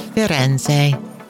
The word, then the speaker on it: Firenze: This is the first of two episodes on the city of Firenze. That is the proper Italian pronunciation of Florence.